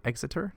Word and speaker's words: Exeter: Oh, I'm saying that correctly. Exeter? – That's, yeah.